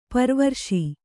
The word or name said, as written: ಪರಿವರಿಸು